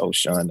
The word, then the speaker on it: Oshun: Ocean. I think it's Ocean. That's how you say it. O-S-H-U-N.